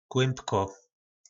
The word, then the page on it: klymbko: po našimu